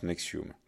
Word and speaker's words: NXIVM: Donc ça s'écrit N-X-I-V-M, tout en majuscule, et ça se prononce Nexium.